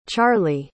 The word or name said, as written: charly